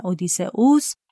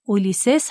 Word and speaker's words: اودیسئوس: منظومه اودیسه هم مثل ایلیاد 24 تا سرود داره و تمام اون شرح سفر و بازگشت پادشاه و پهلوان یونانی به اسم اودیسه اوس یا با تلفظ رومی اولیسس هست که بعد از جنگ تروا به کشورش ایتاکا برمیگرده.